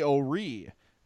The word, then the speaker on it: O'Ree: Willie O'Ree, 1958, broke the NHL color barrier. Am I saying that right? – Yep.